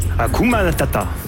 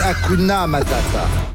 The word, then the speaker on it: Hakuna Matata: Hakuna Matata. – Non, c'est Hakuna Matata.